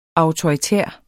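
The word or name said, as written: AUTORITÆR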